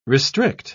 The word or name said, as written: restrict